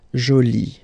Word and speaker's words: jolie: So you can see that you put this E, but then you don't pronounce it, okay, so it's jolie. So basically the two adjectives, masculine jolie and feminine jolie, are pronounced the same way.